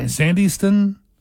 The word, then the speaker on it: Sandyston: Sandyston? – Sandyston and Hardiston. But they pronounce the Y just like it's a short I. How about Sukasana, who people who live there call it Sukasana, like a sauna, S-A-U-N-A.